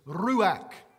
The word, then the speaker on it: ruach: It's ruach. I love saying it because you have to roll your R, and I'm Irish and I can do that.